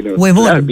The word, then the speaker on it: huevón: Porque, con respeto, lo digo, Fernando tenía el hueón a flor de boca. – Total. Entonces, bien pronunciado, huevón, por supuesto, como lo pronuncian los chilenos.